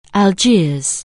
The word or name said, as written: Algiers